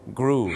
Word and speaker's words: grew: พวก verb ช่อง 2 ที่เป็น ew จำเลยนะครับมันจะต้องออกเสียงสระอูครับสระอูถูกต้องครับอย่างเช่น grow ก็ต้องกลายเป็น grew